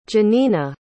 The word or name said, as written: jenina